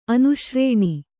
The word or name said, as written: ಅನುಶ್ರೇಣಿ